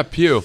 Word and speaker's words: Pugh: Florence Pugh, am I saying that right? – Yeah, Pugh. – Phenomenal. – Like P-U as one word, Pugh. – Is it like P-E-W? – No, it's P-U-G-H.